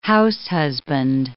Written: househusband

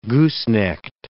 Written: goosenecked

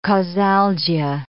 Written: causalgia

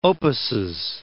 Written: opuses

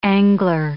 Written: angler

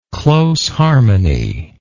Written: close harmony

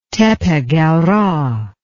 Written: Tepe Gawra

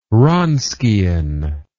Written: Wronskian

